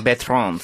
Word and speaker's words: Bertrand: Bertrand.